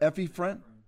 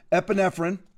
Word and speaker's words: epinephrine: Epinephrine. Thank you. Thank you. God bless you. Epinephrine.